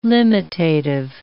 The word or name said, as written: limitative